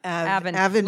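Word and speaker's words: Avan Jogia: Avanjogia. Like Evan.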